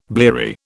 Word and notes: bleary: British English

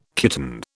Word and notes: kitten: British English

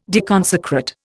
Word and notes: deconsecrate: Indian

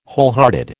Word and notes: wholehearted: American English

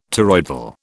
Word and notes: toroidal: British English

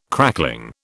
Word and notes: crackling: British English

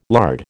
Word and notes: lard: American English

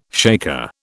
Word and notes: shaker: British English

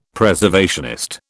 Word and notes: Preservationist: British English

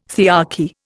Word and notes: thearchy: Indian

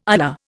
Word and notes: ular: Indian

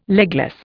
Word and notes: legless: Indian

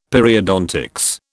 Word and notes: Periodontics: British English

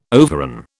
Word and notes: overran: British English

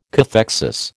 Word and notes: cathexis: American English

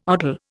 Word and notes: odal: Indian

